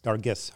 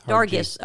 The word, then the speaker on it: Dargis: Dargis. – Dargis, okay.